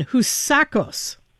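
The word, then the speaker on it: Housakos: I'll take another run. Houssakos. – Perfect.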